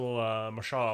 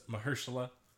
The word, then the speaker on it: Mahershala: I was really into Luke Cage until Mahershala Ali died. – Hmm. – And then I didn't care anymore. – I believe it's Mahershala.